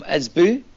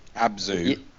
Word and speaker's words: Abzu: Oh, yeah, I've played Ezbu. – Abzu.